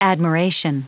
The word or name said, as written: admiration